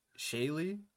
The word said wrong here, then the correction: Shaily: Shaily, hey.